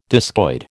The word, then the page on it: discoid: American English